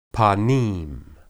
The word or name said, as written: panim